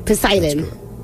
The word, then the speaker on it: Poseidon: Poseidon.